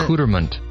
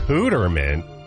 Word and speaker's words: Accoutrement: Accouterment? – Accouterment? – I mean, if you look at it, it looks like it could be pronounced that way. – I mean, that is the correct English pronunciation.